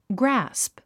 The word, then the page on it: grasp: grǽsp; グラスプ